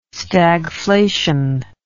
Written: stagflation

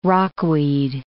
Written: rockweed

